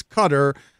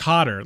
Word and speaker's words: Qatar: First of all, I think it's Qatar. I've been corrected on that before. I pronounce it Qatar as well, but yeah, I think it's Qatar. I've heard Cotter, like welcome back, Cotter. Tomato, tomato.